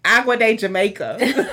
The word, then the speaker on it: agua de Jamaica: I'm like, Agua de Jamaica.